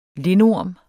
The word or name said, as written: lindorm